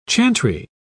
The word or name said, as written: chantry